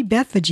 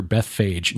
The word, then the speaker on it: Bethphage: That's why we see Bethphage mentioned. – Okay. And I you're right. That is the correct pronunciation. I'd always thought, looking at it from the vantage point of an English speaker, Bethphage.